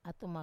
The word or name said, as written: atuma